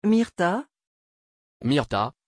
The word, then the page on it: Myrta: French